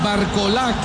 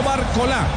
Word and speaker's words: Barcola: Recomendaciones de los franceses: no Tchouaméni, Tchouaméni, sin el acento en la última letra, y no Barcola, Barcola. Así es la pronunciación de estos dos astros franceses.